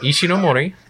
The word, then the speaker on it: Ishinomori: How do you say that? – Ishinomori. – Ishinomori. – Shotaro. – Shotaro. – Yep.